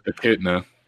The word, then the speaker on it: echidna: Echidna.